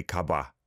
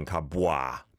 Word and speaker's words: 가봐: As I've said before, with the wa vowel sound, a lot of times it's not overpronounced. So, bwa, I guess most people would say 가봐, 가봐. Rather than kabwa, bwa. You don't have to overly pronounce that.